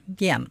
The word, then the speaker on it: Jaen: Felix Jaen. Jaen. Vi fick ju hjälp här på sista på raden under hur man skulle uttala det.